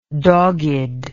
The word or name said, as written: doggedness